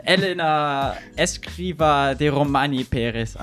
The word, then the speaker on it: Elena Escrivá de Romaní Pérez: So I'm really excited, especially since today's guest is, I'm going to pronounce this in a very German way, Elena Escrivá de Romaní Pérez.